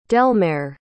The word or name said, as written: delmare